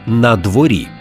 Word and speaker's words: на дворі: Розглянемо перший, менш поширений – «на дворі». Наголос на «і» і пишемо окремо. Це прийменник з іменником.